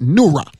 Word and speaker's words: Noohra: Okay, there is a word for Aramaic for light called Noura, which is spelled N-O-O-H-R-A, Noura, which means the light of God.